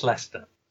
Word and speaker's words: Leicester: I've heard Leicester in the past, but it's Leicester.